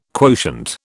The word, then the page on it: quotient: British English